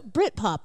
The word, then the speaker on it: Britpop: I think we need to say it like one word. – Britpop.